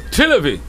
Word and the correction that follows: Tel Aviv: Tel Aviv.